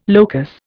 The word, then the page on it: locus: Indian